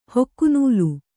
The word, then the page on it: ಹೊಕ್ಕುನೂಲು: Kannada